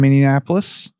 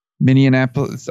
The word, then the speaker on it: Minneapolis: I was not in Minneapolis. Minneapolis. I still add a syllable.